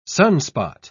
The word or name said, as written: sunspot